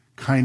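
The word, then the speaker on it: kainē: That's a K-A-I-N. That's their N, and that's a long A sound. Kine.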